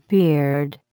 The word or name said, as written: beard